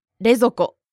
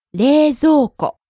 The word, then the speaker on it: 冷蔵庫: It's not 冷蔵庫 This word contains a long O sound, so be careful. – そうですね。冷蔵庫 Actually, the first 冷 part becomes 冷。